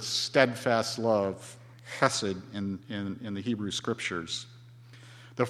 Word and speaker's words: chesed: chesed.